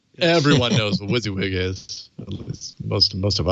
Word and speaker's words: WYSIWYG: And he said, I'm going to say that, pronounce that WYSIWYG.